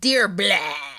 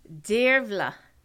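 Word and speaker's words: Dearbhla: It's dear blah. no, no. It's Dirvla. Dirvla.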